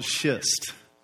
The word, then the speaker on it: schist: I have to be very careful how I pronounce that. It was called schist.